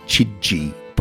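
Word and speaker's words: Chidgey: Anyway, so we look forward always to and they pronounce it Catherine Chidji, by the way, the Axeman's Carnival on the ABC.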